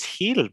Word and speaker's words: Gil: I think it's Heel, bro. – Heel? – I'm looking it up right now. The pronunciation of Bryan, well, it would be Heel.